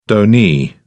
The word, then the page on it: donee: British English